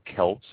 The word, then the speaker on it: Celts: Viticulture dates back, solidly dates back, not thinking or anything, far back to 700 B.C., the Celts or Celts, however you would like to pronounce it, used wine for rituals and a lot of daily consumption within the area of Austria.